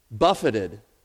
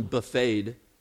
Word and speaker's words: buffeted: Then the next description says he was buffeted. Be careful not to pronounce this one buffeted. That's the contemporary application for the Baptist churches in America today. You know, something about buffet lines.